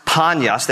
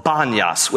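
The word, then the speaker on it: Banias: But the, the Arabs can't, in Arabic language, they cannot pronounce panyas. They can't say the letter P, so it is called banyas with a B.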